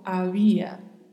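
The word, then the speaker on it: había: Haver em português, havia e haver, em espanhol, havia. A pronúncia tem que ser cuidadosa porque essa letra B é pronunciada com leve som de V.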